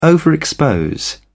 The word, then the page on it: overexpose: British English